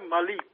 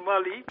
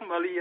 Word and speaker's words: Malí: Bueno, lo primero yo voy a pronunciar Malí, porque aquí decimos Mali, pero Sí. – en realidad es Malí, es una influencia anglófona.